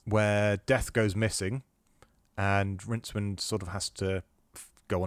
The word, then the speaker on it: Rincewind: The star of the first couple of books he did, the main character, was a wizard called Rincewind, and that's who you play in Rincewind. – Rincewind, yes.